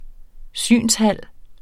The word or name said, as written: synshal